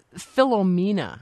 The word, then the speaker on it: Philomena: It's Philomena.